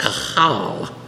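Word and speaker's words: qahal: Now it's a very guttural word. I'm not going to print. It sounds like you have a large phlegm build up in your throat to properly pronounce it. If you're to transliterate it into English, it's Q-A-H-A-L.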